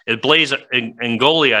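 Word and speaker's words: Ingoglia: Representative Igon, his name is Ingogli. Blaise Ingolia, I think, is how you actually pronounce it.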